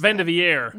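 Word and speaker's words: vivandière: Unless you're a Valerie, is that how you pronounce it? – Vendivier. – There we go. – Yes, the French pronunciation.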